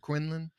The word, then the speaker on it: Quinlan: He disappeared up in Quinlan, correct? Quinlan? – Yes. – Is that how you pronounce it? – Quinlan, Texas.